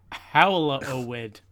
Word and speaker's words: hallowed: The correct pronunciation of hallowed contains three syllables. Hallowed.